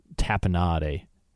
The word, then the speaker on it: tapenade: Pisano's in Albuquerque, that's the restaurant, their sign said, Black Olives Matter, try our tapenade. That's how you pronounce that.